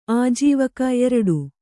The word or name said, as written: ಆಜೀವಕ